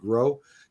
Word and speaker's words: Groh: That's how you pronounce it, Groh.